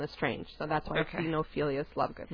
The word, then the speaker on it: Xenophilius: How do you say his name? – Xenophilius Lovegood. – Xenophilius? – Like, yeah, you know, because, like, bibliophilia is to love books, and xenophobia is to be afraid of open spaces.